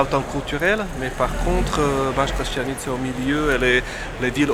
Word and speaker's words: Banská Štiavnica: Banská Štiavnica.